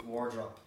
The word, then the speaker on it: Wardrop: Today we're very lucky to be joined by the wonderful Ken Wardrop. Am I saying that right? Wardrop? – Oh yeah, wonderful. It's just the right word. – Wardrobe? – Wardrop. You won't be the first to have said wardrobe.